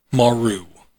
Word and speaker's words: Maru: And I'm going to say this is pronounced Maru. M-A-R-U.